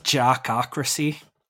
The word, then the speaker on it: jockocracy: the jockocracy, I think that's how it's pronounced. – Go with jockocracy. – I'm trying to say that. It's not working. Jockocracy. There we go. I'll separate the syllables explicitly.